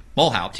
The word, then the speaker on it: Mulhaupt: And bass is Joe Mulhaupt. I have it written down, spelled out phonetically. Mulhaupt.